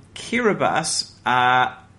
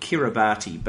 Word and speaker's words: Kiribati: And Kiribati looks like it's spelt Kiribati, but apparently it's pronounced Kiribati.